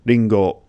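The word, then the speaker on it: ringo (りんご): For example, the Japanese word for apple is ringo, ringo. Okay, I always remember this. I always remember the Japanese word for apple. In my pronunciation it's Ringo, but in Japanese it would be like Ringo.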